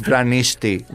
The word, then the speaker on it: Vranishti: My last name is Vranishti.